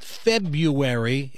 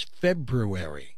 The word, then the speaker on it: February: It's not February if you're listening in news and traffic. It's not February. It's February.